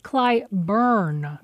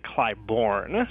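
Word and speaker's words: Clybourn: decided to pronounce Clyburn. – But it was brought to our attention after the initial recording was done that local Chicagoans, myself included, typically pronounce it Clyburn.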